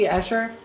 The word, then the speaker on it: Escher: I believe it's pronounced hammer. – No. – Is it M.C. Escher? Isn't yeah. – it? – Yes. – Yeah. Or was I making that up? – No, that's right.